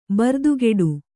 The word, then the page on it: ಬರ್ದುಗೆಡು: bardugeḍ